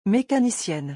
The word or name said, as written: mecanicienne